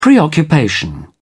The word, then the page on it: preoccupation: British English